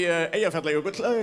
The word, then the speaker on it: Eyjafjallajökull: And for like a week, everyone was like, how do you pronounce that? Is it Eldjárn? And we could be like, oh, let me tell you how to pronounce it. It is actually Eyjafjallajökull. It's not that hard.